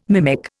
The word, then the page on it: mimic: Indian